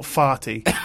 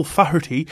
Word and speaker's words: Faherty: F-A-H-E-R-T-Y, Faherty. Oh, I think if I remember correctly, he's Irish. So okay. – Faherty, probably, something like that. Not Farty. Sorry. My mistake, my apologies. Michael Faherty passed away at his residence in Galway, Ireland.